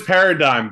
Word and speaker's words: paradigm: I know it's paradigm, but I like playing with it. Did you know? – I knew enough that he had it wrong. – That's hillbilly English right there, where you're like, I know that's not right. How about that? – I know there's silent letters. One of those letters has to be silent.